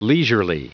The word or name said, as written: leisurely